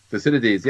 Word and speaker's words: Thucydides: Am I pronouncing him correctly, by the way? – Thucydides, yep.